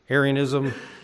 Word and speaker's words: Arianism: So we're not talking about white supremacy. That's Arianism. Of course, didn't I just say Arianism? Yes, but it's spelled differently, A-R-Y-A-N-I-S-M. That's the white supremacy Arianism. We're talking about Arianism, A-R-I-A-N-I-S-M, after Arius, who was a teacher and elder at the Alexandria Church in Egypt.